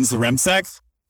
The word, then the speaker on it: Zrimsek: And so on today's episode, super, super, super excited uh, to have Brian Zrimsek.